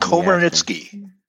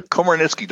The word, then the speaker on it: Komarnyckyj: Komarnyckyj. – Dig it. – Say it again. – Komarnyckyj. Don't try to read it when you say it.